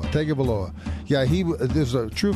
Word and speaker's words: Tagovailoa: And how do you pronounce his last name? Taglia. – That's it. – Tagovailoa. – That's it. I think you got it right.